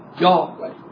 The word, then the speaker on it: Yahweh: All scholars today will tell you that the proper pronunciation of this name is Yahweh.